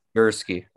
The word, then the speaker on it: Gurske: We are joined by 6 foot guard Miles Gurske. Am I saying that right? – Gurske, yeah. – Gurske.